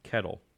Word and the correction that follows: Kittle: Kittle. Excuse me.